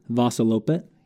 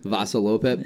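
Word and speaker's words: Vasaloppet: the Vasilopet. That one. How do you say it again? – Vasilopet. – Vasilopet.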